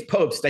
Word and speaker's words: Pobst: You know, I asked about, you know, lap times, because Randy Post, if I always mess his last name up. Did I say that right? – Yeah, I think it's Pobst.